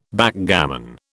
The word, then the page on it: Backgammon: British English